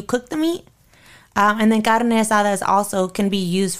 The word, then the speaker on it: carne asada: So I want to get back and talk to you about carne asada. Am I saying it right? – Yep, carne asada.